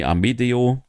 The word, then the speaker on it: Amedeo: Dann haben wir noch Four-Way-Match Amadeo. Nee, Amedeo, wo wir richtig aussprechen, besiegt Fast Time Mudo, Joseph Alexander und Starboy Charlie.